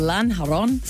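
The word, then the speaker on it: Lanjarón: It's called Lanjaron. – Lanjaron. – Oh my Lanjaron. – God. And okay, pronunciation. – Every time I see a J in Spanish, I'm like, I know that sounds like H.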